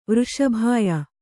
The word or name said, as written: ವೃಷಭಾಯ